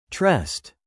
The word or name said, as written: tressed